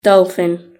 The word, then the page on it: dolphin: British English